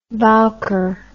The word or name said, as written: bowker